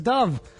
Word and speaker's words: Dove: Bueno, pero hay incluso marcas, ustedes reconocen el jabón Dove, en inglés es Dove. – Claro. – Dove. No es Dove.